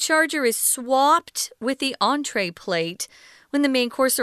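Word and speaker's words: entrée: The reason why that word entree has that funny line over it is because it's French. So it's pronounced entree.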